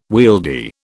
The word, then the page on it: wieldy: British English